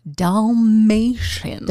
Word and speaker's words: Dalmatians: Say doll. – Dalmatians. Matians. – Now put it together. Dalmatians. – Dalmatians. – Good job. – Okay. – You said it right. – Thank you. – Now let's say it wrong. – Written by John Hughes. Based on the book, The 101 Dalmatians. – No. You got the N in there.